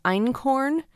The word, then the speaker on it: Einkorn: They use Kamut a lot as well as Einkorn. It's not corn. It's corn spelled with a K.